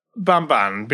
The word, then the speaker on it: Bamban: Yeah, Bamban, like Flintstone. – What can you tell me about Bamban?